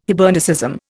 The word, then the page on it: Hibernicism: Indian